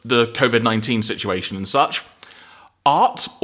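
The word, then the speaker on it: Arte: It is Arta. So it's basically, it's the Italian for art. And so given that we have an Italian working in our office, we should be very careful how we pronounce this one, I guess. – So basically, folks, there is an accent on the E. So Arta, yeah.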